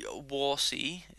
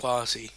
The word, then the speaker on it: Warsi: Lady Warsi, is the, do I, am I pronouncing that right? – I think it's Wazi. – Wazi, okay.